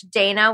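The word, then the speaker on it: Dayna: But if you're on LinkedIn and you search Dana with a Y, D-A-Y-N-A, and then if you just try and sound out Charfolia, even if it's spelt wrong, I should pop up.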